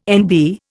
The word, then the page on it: NB: Indian